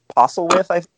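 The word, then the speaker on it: Postlethwaite: Yeah, and Pete Postlewith, his last name is pronounced.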